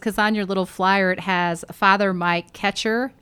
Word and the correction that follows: Kuchar: And I love Kuchar. I always say that wrong. Father Mike Kuchar.